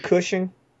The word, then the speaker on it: Cushing: Isn't it Cushing? – Yeah, sorry. I mispronounced it in my head.